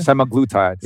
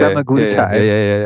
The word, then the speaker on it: semaglutide: It's called semaglutide or something? – Semaglutide. – Yeah, semaglutide.